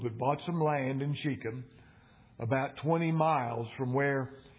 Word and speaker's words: Shechem: Shechem is the way it's pronounced. Shechem.